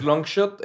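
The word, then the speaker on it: LNGSHOT: Sí, no, es, es Longshot, LNG, diagonal, H T, Longshot.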